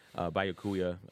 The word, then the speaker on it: Byakuya: Byakuya Kuya, I'm saying that wrong again. – Byakuya and Renji collapse, and they are in critical condition.